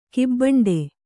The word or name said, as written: ಕಿಬ್ಬಂಡೆ